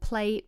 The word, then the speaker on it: Pley: Um, but anyway, she has launched a beauty line called Pley Beauty, but it is spelled P L E Y. So when you're searching this, don't search A Y,